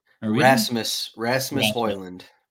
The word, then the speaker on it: Rasmus Højlund: Rasmus Højlund.